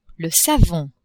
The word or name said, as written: savon